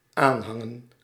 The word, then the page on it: adhere: American English; /ædˈhiɹ/